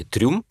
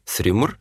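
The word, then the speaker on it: Þrymr: И хитрый великан, который непосредственно за это отвечает, который непосредственно похитил молот, великан по имени Трюм, Трюмр, древнескандинавское произношение, по-русски пишут Трюм или Трим иногда.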